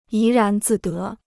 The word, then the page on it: 怡然自得: yí rán zì dé